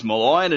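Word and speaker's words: Marlion: The entire footy media is mispronouncing the name of Richmond debutant Malion Pickett. He even said his name is Malion in an interview with The West, Steve Butler.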